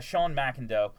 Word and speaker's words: McIndoe: Down Goes Brown tweeted, Sean McIndoe. That's how you say it, right? – Yeah.